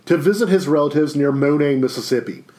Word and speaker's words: Money: I can't say it one way or the other, but it's pronounced Monet in the Delta, Northwest Mississippi.